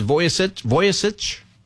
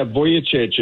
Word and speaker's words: Vujicic: Is Vujicic? – You did, yeah. Vujicic.